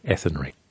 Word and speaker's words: Athenry: I said it wrong the first time. I'm sorry. Athenry.